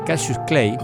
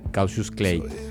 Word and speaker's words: Cautious Clay: Se escribe Cautious Clay, se pronuncia igual Cautious Clay.